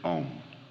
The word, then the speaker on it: Om: That sound is Om. Now, in Sanskrit, the syllable Om, the sound O, is analyzed into two sounds, A and U, joined, A, U, M. So you'll sometimes see this written O-M and sometimes A-U-M. When written A-U-M, it is called the four-letter sound.